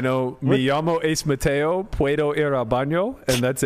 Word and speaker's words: puedo: Puedo. – Puedo. – No. – The D should be like a TH. So yeah. It's puedo.